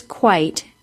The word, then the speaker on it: quite: It is that E, that silent E, that causes quite to be said with a long I. Remember, the long I sounds like I, quite.